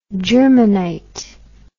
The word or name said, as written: germanite